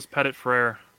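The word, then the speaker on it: Petit-Frere: How do you say the Ohio State tackle's name? – Nicholas Pettit-Frere. – Okay, so I was going to say it correctly.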